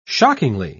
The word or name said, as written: shockingly